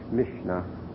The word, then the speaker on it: Mishneh: The word Mishnah is Strong's number 4932, and that's spelled M-I-S-H-N-E-H, Mishnah.